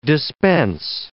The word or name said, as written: dispense